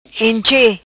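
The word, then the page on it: Enche: Patua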